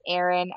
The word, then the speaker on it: Erinn: It's Erin underscore Bridgman my name is spelled different because my parents had to do that to me, I guess. So it's at Erin, E R I N N underscore Bridgman, B R I D G M A N.